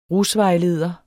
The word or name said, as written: rusvejleder